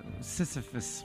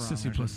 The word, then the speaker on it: Sisyphus: That seems like a Sisyphus. What is that? I'm saying that wrong. – Sisyphus. – Sisyphus.